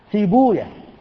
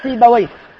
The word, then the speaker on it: سيبويه: وجاء عندنا كما حكى عالمان جليلان استخدام زعم بمعنى قال، أول هذين في بوية إمام أهل اللغة، ولعلكم تستغربون نطقه هكذا، ينطقه المحدثون سيبويا، وهو مشهور عند الناس سيبويه، وإلا المحدثون يقولون سيبويا وحمدويا وراهويا ونفقويا وخالويا وهكذا إلى غير ذلك. لماذا؟ لأنهم سمعوه سماعا، وهذه أسماء أعجمية، فلما كانت أعجمية عدنا فيها إلى لغة أهلها نسمع كيف ينطقونها فننطق كما نطقوا، وهم ينطقونها سيبويا وحمدويا وخالويا ونفقويا وراهويا فلم يتعدوا السماع.